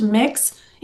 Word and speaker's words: Mx: It says, starting with the obvious, MX, apparently pronounced mix, is a made up term describing an individual who does not wish to identify her gender.